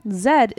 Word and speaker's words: Zed: The pronunciation Zed is more commonly used in Canadian English than Z. As Zed is the British pronunciation.